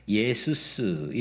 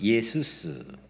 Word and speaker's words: Iesus: I E S V S I E S V S 예수스 이렇게 라틴어로 불렀습니다. 이게 영어로 영어로 I E S U S 발음은 똑같습니다. 영어로도 예수스 이거를 거의 천년 넘게 영어로 예수스, 예수스, 예수스, 영어로 이렇게 사용했는데 후에 뭐 사실 근자입니다.